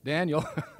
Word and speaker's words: Daniel: Remember that story in Daniel? Put an extra syllable in there. Daniel, where these three guys are thrown into the fire, and when Nebuchadnezzar looks, there's a fourth guy there.